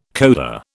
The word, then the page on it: coda: British English